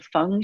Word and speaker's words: Feng: It's F-E-N-G, but it's pronounced Feng. So it's F-E-N-G. Then Shui is spelled S-H-U-I.